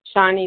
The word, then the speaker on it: Shani: Shawnee B. – I heard, is it Shawnee? – Yes. – Shawnee. How do you spell that? – S-H-A-N-I.